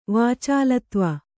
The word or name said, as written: ವಾಚಾಲತ್ವ